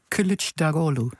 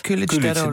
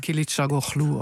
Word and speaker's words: Kılıçdaroğlu: Zo zeg je dat. – Kılıçdaroğlu. De I is een U, hè? – Ja, en de G met een dakje erop, die spreek je niet uit. Die verlengt de klinken daarvoor. Dus niet Kılıçdaroğlu of zoiets.